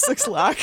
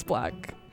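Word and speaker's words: 6LACK: That's how you say his name. His name is Black.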